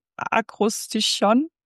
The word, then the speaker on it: Akrostichon: Und Heike kennt so Wörter wie Akrostichon. Habe ich das richtig gesagt? Genau.